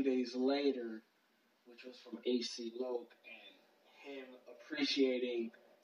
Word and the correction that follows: HC Loke: I love how you pronounce AC. It's HC Loke.